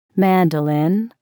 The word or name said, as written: mandolin